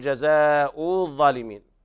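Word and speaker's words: جزاؤا: وذلك جزاء الظالمين، طبعا هذه الواو الهمزة المرسومة على واو تنطق همزة فقط لا تنطق واو، لا نقول جزاء الظالمين، لا، ما في مد، هذا الرسم العثماني، فتنطق جاء زي شاء وجاء وحاق وما جاء على شاكلتها.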